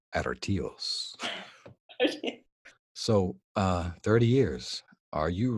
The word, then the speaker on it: Artios: And won the, I'm not sure how to say it, Artios Award for Best Location Casting in 2017 for Me and Earl and the Dying Girl. – Yes, it's Artios, actually, so.